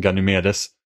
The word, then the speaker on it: Ganymedes: Vem, har tagit den, vem tar den största månen och döper den till ett jobbigt namn som Ganymedes? – Jag vet inte ens om det är så det uttalas. – Nej, det är det, så det bokstaveras. – Det är så vi säger det.